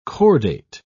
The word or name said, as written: cordate